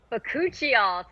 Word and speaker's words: bakuchiol: Ooh, bakuchiol.